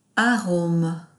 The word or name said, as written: arome